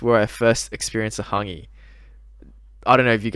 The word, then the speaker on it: hangi: Oh, it's pronounced hangi, but I think, but yeah. It's spelled H-A-N-G-I.